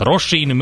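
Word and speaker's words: Róisín: Az ő nevét Rosinnek kell ejteni, tehát Rosin Murphy és egy nagyon kerek születésnapot ünnepel.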